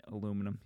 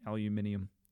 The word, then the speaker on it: aluminium: He's got the voice of an angel, and the way he says aluminum, he says like aluminium, which is how they say it in the United Kingdom, I think, or England, or, you know, aluminium.